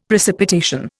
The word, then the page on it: precipitation: Indian